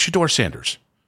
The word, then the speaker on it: Shedeur Sanders: But there are other people who cannot say the damn name right. It's Shedeur Sanders.